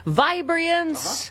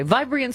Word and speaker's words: Vibrant: 20 minutes after the hour, this portion of the show brought to you by Vibrance. Uh-huh. Right? It's just my favorite face cream, but I did the extra syllable and the wrong emphasis for a reason.